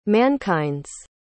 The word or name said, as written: mankind's